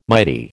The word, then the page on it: mighty: American English